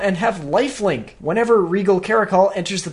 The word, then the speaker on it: caracal: Regal Caracal? – Oh, yeah, the Desert Cat. – That's how you pronounce it, right? Caracal? – Caracal.